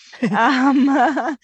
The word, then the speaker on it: pelargonium: Pelargonium? – And, yeah. Say it again. Say it. – Pelargonium. – Okay. So there is an R, pelargoniums.